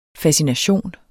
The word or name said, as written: fascination